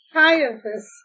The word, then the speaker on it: Caiaphas: Caiaphas and John and Alexander and as many as were of the kindred of the high priest were gathered together at Jerusalem.